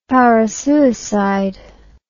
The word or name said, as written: parasuicide